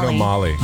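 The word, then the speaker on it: Bruno Magli: Bruno Molly. – Molly. So the G. So the G is silent.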